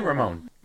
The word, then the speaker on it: Ramón: Maybe try to roll your R's too much. Just say Ramón.